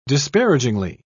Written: disparagingly

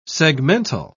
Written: segmental

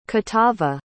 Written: catava

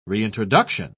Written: reintroduction

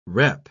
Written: rep

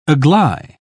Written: agley

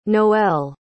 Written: noele

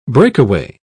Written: breakaway

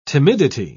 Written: timidity

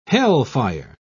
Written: hellfire

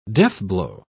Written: deathblow

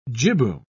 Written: jibboom

